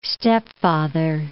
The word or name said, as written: stepfather